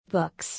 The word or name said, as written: Books